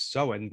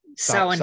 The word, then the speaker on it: Samhain: which I have heard pronounced as Samhain, but you taught me the correct pronunciation from your experiences in Ireland. It's not Samhain, it is, how did you say it again? – Samhain.